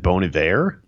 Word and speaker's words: Bon Iver: You said that Bon Iver? – That's how you say it.